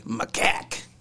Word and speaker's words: macaque: The way that it's spelled out here, it's M-U-H hyphen. – Macaque. – K-A-K. Macaque.